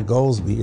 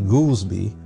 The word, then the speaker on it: Goldsby: And his name is not Goolsbee, it's actually Goolsbee, but people call it Goolsbee because it's spelled G-O-L-D-S-B-Y.